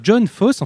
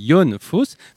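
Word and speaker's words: Fosse: qui s'appelne John Foss. Enfin, il faut prononcer Jon Foss, parce que cet homme-là est norvégien, et ce n'est pas la première fois que vous adaptez son travail.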